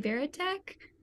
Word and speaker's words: Varitek: You can find me on Instagram at Allie Veritek. It's spelled V-A-R-I-T-E-K, nice and check.